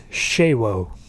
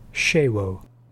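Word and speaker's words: Sewo: Next up, we got running back Siwo Alanalua. He is that number two running back, so you'll see his name pop up plenty. – Josh, I believe it's Shewo. There's a silent H in there. Shewo.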